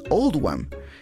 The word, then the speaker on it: Oldham: Esta elección se hizo para reflejar la pronunciación de Oldham en el acento local, que es Oldham.